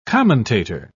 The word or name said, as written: commentator